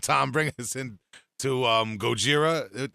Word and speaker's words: Gojira: With it was Gojira. What was the album by Gojira? – Well, it's pronounced Gojira. – With a J.